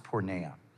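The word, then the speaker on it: porneia (πορνεία): So sexual immorality is the translation of a Greek word that is pronounced porneia.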